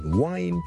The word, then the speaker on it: whine: 例如愛爾蘭嘅英文呢好多時候呢都會聽到 wine W-I-N-E 同埋 W-H-I-N-E 發音係唔同嘅，聽住第一個係 wine wine wine wine wine，第二個係 wine wine wine wine wine，聽落去你嘅感覺咧，當佢好似 H 音之前跟住先加個 wine 咁，wine and wine，咁就唔係錯唔錯嘅問題，而係唔同地方嘅口音。